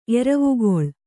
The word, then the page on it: ಎರವಿಗೊಳ್: kannada